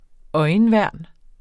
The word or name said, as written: øjenværn